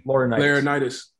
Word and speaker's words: Laurinaitis: Laurinaitis. – Yeah, Laurinaitis of Ohio State.